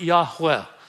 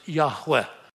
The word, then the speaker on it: Yahweh: We usually pronounce it Yahweh. Y-H-W-H.